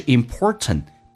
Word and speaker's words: important: 第二个是 important, t 也可以，第一个 t 不发音叫 important, 你要能听懂。